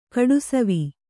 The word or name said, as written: ಕಡುಸವಿ